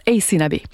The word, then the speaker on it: Aysanab: Alors, je vous épelle son nom, c'est A-Y-S-A-N-A-B. Ça se prononce Ace Nabé.